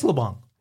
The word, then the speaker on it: LeBlanc: Not in South Louisiana. – So during the intro, when Friends would run on television in Louisiana, they would say his name, they wouldn't say the city. – Absolutely. – It would just be Matt LeBlanc. – That's what they used to call him. – And it's okay. – LeBlanc. – It is LeBlanc.